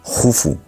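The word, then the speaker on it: Khufu: Snefru deja todo para la siguiente generación, y eso lo aprovecha muy bien su hijo Keops, que, por cierto, para cultura general, se escribe Keops, pero se pronuncia Jufu, y casi nadie llama Jufu, digo, porque Herodoto nos lo tradujo del griego, y bueno, pues ahí se ha quedado, ¿no?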